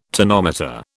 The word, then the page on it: tonometer: British English